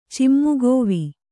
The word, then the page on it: ಚಿಮ್ಮುಗೋವಿ: cimmugōvi